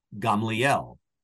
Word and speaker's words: Gamaliel: Gamaliel, mm-hmm. whom you see not only in Acts chapter 5, we usually say Gamaliel, right? But Gamaliel is how we pronounce it.